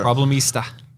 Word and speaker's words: Problemista: And this is Problemista. Is that how you say it? – Yeah. Yeah. Problemista.